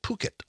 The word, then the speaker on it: Phuket: It turns out in Thailand, P-H is pronounced with puh. And the U is oo. The city is called Phuket.